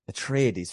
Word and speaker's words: Atreides: Atreides.